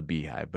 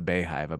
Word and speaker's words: Beyhive: I wanted to call it the Beehive, but it's the Beyhive, I believe.